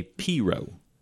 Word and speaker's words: pirogue: A Cajun canoe is called a Piro. – I'm into that. – But it's spelled P-I-R-O-G-U-E. – Yes. – I think maybe just spell it P-I-R-O-W.